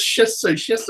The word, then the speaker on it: Shih Tzu: I said, there's no T in it. Shih Tzu. Say it right, Joe.